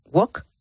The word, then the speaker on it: walk: Y luego está walk. ¿O walk? – Walk. – Walk. – Walk. Walk. – Walk. – ¿Qué cómo se dice? – Walk. – Walk, sin la L. – Sin la L, claro.